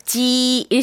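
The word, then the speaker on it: 지 (鸡): 이건 일성으로 발음을 해야 될까요? – 네, 한번 맞춰볼게요. – 그렇죠? 어, 지 일성입니다.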